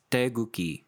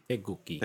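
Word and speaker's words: Daeguki (태극기): It's pronounced Daeguki. – Daeguki. – Daeguki. – Yeah. – Daeguki. – Yep. It's all soft. I mean, there is technically a K that's written, but it's basically all soft G sounds.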